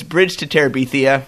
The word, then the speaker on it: Terabithia: The last two movies coming out this year, under the Disney label anyway, are Bridge to Terabithia. Did I pronounce that right? – That's not shocking. It's Bridge to Terabithia from our friends at Walden Media, who also made Chronicles of Narnia.